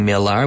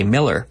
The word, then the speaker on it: Millar: Sótano, también bodega, cellar, C-E-L-L-A-R. C-E-L-L-A, not vendedor, no, cellar, C-E-L-L-A-R, but it's pronounced cellar, not cellar. Just like my name is spelled Millar, but we don't say Millar, we say Miller.